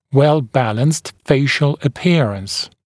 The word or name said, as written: well-balanced facial appearance